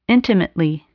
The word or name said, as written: intimately